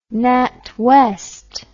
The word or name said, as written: natwest